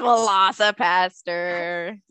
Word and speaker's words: Velocipastor: Is it Velocipastor? – It's Velocipastor.